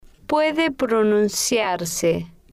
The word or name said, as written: puede pronunciarse